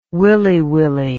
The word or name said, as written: willy-willy